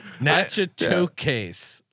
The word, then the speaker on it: Natchitoches: Natchitoches, yeah. Louisiana. How do you pronounce it? – Natchitoches.